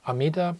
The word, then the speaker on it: Amida: Amida is the Japanese pronunciation of the Sanskrit word Amita, not measurable.